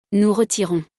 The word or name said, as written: nous retirons